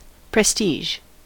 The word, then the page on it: prestige: American English